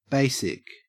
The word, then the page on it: basic: Australian English